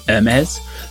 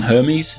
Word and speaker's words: Hermès: I had to brush up on my pronunciation of Hermès for this rather than Hermes, which I was told is definitely wrong.